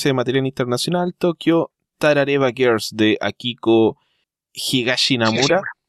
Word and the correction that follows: Higashimura: Pues pusiste una sílaba, además no tiene ningún na. Es Higashimura.